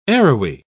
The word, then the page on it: arrowy: British English